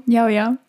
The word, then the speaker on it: Yao Yao: But we went to Yao Yao, the ice cream shop. Is it pronounced like that? I don't know. – Yao Yao. That's how I pronounce it.